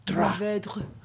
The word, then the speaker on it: Mourvèdre: 87% Pinot Noir, 9% Syrah, 3% Grenache, and 1% my favorite grape to pronounce, Mourvèdre. – Mourvèdre.